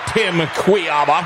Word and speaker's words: Kujawa: And, of course, our guest host today, let's give him another round of applause, Mr. Tim Kujawa.